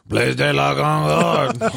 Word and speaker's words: Place de la Concorde: It's actually pronounced Place de la Concorde.